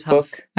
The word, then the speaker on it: Hough: I did know it was Hough.